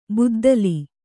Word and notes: ಬುದ್ದಲಿ: kannada

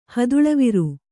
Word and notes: ಹಿತ್ತಿಲವರೆ: hittilavare

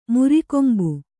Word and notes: ಮುರಿಕೊಂಬು: Kannada

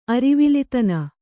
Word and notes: ಅರಿವಿಲಿತನ: kannada

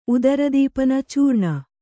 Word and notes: ಉದರದೀಪನ: udaradīpana

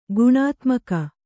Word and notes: ಗುಣಾತ್ಮಕ: guṇātmaka

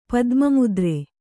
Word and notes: ಪದ್ಮಮುದ್ರೆ: padma mudre

